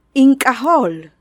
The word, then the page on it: In k'aj óol: Maya